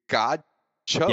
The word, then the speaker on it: Godchaux: Davin Godcho. His name is spelled Godchaux. – You know he's from Dubai. – Godcho. – Yes.